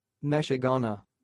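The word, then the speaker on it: meshuggener: The pronunciation of this word sounds like Meshugana.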